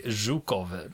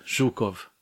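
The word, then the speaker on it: Zhukov (Жуков): De, flera av er kände, jag får fråga, hur var det med uttalet? – Jo, det var eh, godkänt. Zhukov. Zhukov. – Ja, ja, det är godkänt.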